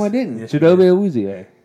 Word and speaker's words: Chidobe Awuzie: Chipotle Awuzie. – Not Chipotle. – Chidobe Awuzie.